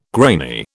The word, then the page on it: grainy: British English